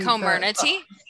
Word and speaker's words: Comirnaty: Comirnaty.